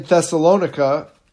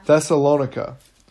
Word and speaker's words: Thessalonica: For even in Thessalonica, or Thessalonica, man, I can't talk tonight. – I think you said it right the first time. – Thessalonica.